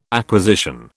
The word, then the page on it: acquisition: British English